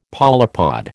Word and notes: polypod: American English

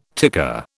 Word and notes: ticker: British English

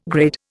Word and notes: grid: Indian

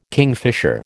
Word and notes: kingfisher: American English

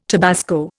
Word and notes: tabasco: Indian

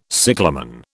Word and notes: cyclamen: British English

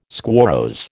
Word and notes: squarrose: American English

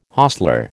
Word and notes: hostler: American English

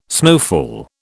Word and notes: snowfall: British English

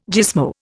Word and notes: gismo: Indian

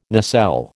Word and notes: nacelle: American English

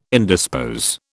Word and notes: indispose: British English

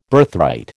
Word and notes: Birthright: American English

